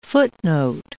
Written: footnote

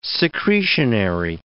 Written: secretionary

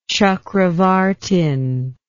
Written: chakravartin

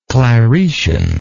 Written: claretian

